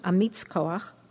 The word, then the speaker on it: Amitz Koach: The second stanza mentions the Sabbath as one of the Ten Commandments given by God, the all-powerful one, Amitz Koach, which rhymes with Noach.